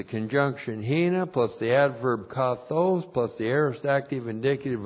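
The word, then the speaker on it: kathos (καθώς): And then we have kathos, comparative adverb. K-A-T-H, long O-S.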